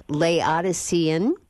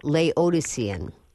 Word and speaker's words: Laodicean: And the word is Laodicean. Or Laodicean. Laodicean.